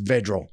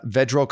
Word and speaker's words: Vedral: Senior quarterback Noah Vidral comes into this game with more interceptions, four, then TD passes, three. Actually, I think his last name is pronounced Vedral. Last week against Indiana, Vedral completed 21 of 34 pass attempts, but only for 130 yards.